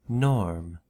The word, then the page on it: norm: American English